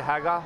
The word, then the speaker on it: Hagar: You actually, it's pronounced a little bit differently. You actually wouldn't pronounce the last R, so it'd be Hagar.